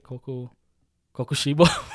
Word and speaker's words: Kokushibo: I think his name was Kokubushi. Is that right? Kokushibo? Kokubushi. Sorry, I'm mixing up the syllables. Kokushibo,